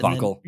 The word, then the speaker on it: Wankel: Wankel, thank you for the pronunciation. – I think that's how you would say it.